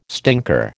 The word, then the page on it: stinker: American English